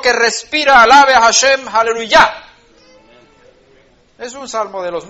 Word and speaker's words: aleluya: Aleluya, en hebreo es aleluya, con el acento en la A final, aleluya.